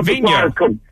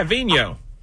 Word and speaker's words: Vigneault: I don't want to say his name wrong. – No, you got it. Vigneault. Yeah, Vigneault.